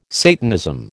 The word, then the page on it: Satanism: American English